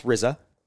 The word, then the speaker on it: RZA: It is pronounced RZA. – Because it's RZA.